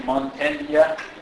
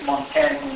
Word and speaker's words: Montaigne: Technically, it's supposed to be Montendia, because when he lived, French pronunciation was different, so technically that's the correct pronunciation. The more common one that I have heard is just Montaigne, and you kind of lose the y at the end.